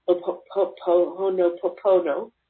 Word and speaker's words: Ho'oponopono: And so the more you can drop down into the heart, the more you can do the ho'oponopono.